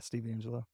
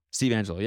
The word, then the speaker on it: Steve Angelo: But it's funny using it in this, like today they posted who's Steve Angelo? Is that his name? From Swedish House Mafia? Swedish House? How did I say that? Like Swedish House Mafia. – Yeah, Steve Angelo. – Steve Angelo, yeah, yeah.